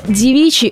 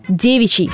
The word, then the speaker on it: девичий: В поэзии или для придания фразам некой старины возможно ударение на «и». Просто «девичий» — это устаревшая форма слова. Но злоупотреблять стариной не стоит. Все же сегодня норма настоятельно рекомендует ставить ударение на первый слог — «девичий».